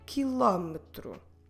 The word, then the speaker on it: quilômetro: Q-U-I-L-O. Com acento M-E. T-R-O. Quilômetro. Again, the U in the beginning, silent. Quilômetro. Quilo. A kilo. Como se escreve quilo em português? Q-U-I-L-O.